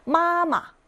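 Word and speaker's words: 마마 (妈妈): 마마, 마마, 마 일성으로 읽었고 뒤에 있는 거 경성으로 읽는다고 했습니다. 짧고 가볍게 있는 성조, 아무것도 성조 표기 안 했죠.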